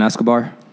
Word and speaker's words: Escobar: Herson Escobar.